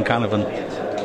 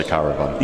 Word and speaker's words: Canavan: I liked your pronunciation advice on Canavan before we went on air. – Yeah. – He said, don't slip up and say caravan.